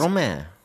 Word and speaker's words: Stromae: Stromae.